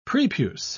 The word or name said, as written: prepuce